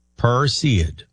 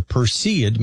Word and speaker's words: Perseid: Am I saying that right? Perseid? I believe so. Perseid. The Perseid meteor shower reaches its peak.